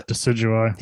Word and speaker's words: Decidueye: And now I have it up to Decidueye or however you pronounce it, like where he's like the Decidueye.